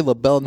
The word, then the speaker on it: libel: It's liable. Huh? It's pronounced liable. If you say libel, no one understands L-I-B-E-L. Libel. – Yeah. – Not liable. Libel. – Libel.